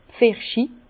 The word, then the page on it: d'r Pfirsich: Bas Rhin; Herrlisheim